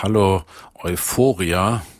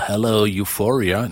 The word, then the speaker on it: Hallo Euphoria: Sie heißt Hallo Euphoria oder Hello Euphoria. Ich glaube nicht, die Sterne haben ja immer Deutsch gesungen. Der Plattentitel wird also auch mit Sicherheit Deutsch ausgesprochen.